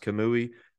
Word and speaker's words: Kamui: I think his name, his first name is pronounced Kamui uh, Kobayashi.